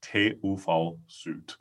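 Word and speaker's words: TÜV SÜD: So just one more time, it's TUV Süd for all of you listening out there.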